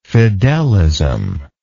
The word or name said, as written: fidelism